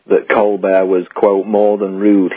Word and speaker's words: Colbert: It's Colbert, but the T is silent.